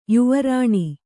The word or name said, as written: ಯುವರಾಣಿ